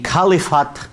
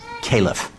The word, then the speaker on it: caliph: Khalifat, in my undoubtedly badly pronounced Arabic, is what we might say in English is caliph. A lot of people will sometimes say khalif.